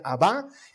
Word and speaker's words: Abá: Y Él nos entregaba a su papá, utilizando una palabra hebrea que se pronuncia, eh, aramea, perdón, que se pronuncia Abá, y que literalmente significa papito.